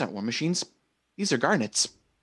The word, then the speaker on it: garnit: Same pronunciation, but more like knit small insect kind of. – Insects. So we have to go bowling again? – G-A-R-G-N-E-T-S? – G-A-R-N-I-T. – Okay. Oh, that kind of knit. I heard Nat. – Yeah, it's all pronounced the same way.